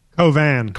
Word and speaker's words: Cauvin: Cauvin.